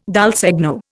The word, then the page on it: dal segno: Indian